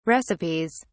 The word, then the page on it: recipes: British English